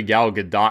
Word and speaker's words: Gadot: It is actually Gal Gadot because she's not French, she's Israeli.